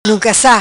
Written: Nuncassa

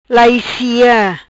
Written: Laissi

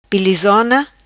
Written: Pilizona

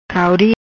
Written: Cauri

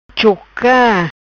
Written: Choca

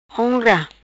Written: Honra

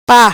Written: pa

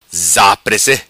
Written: Zaprece

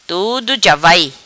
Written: Tudo ja vai

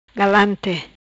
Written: Galante

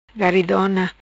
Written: Garidona

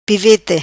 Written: Pivete

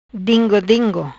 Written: Dingo dingo

